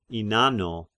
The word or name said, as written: Innano